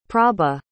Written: prabha

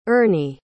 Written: Ernie

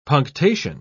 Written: punctation

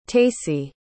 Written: tacy

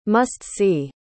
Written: must-see

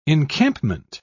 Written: encampment

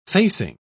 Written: facing

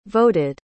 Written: voted

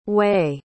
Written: wei

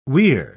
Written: weir

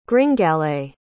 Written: gringalet

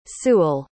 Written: sewell

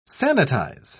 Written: sanitize

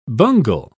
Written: bungle

